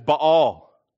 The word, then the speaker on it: Baal: Just on a note of pronunciation, you may have noticed throughout this series I've been pronouncing the Canaanite god, Baal, as Baal. And my kids have given me a hard time about that. They said, "Dad, why can't you just say Baal like everyone else?" I said, "Well, it's not pronounced that way."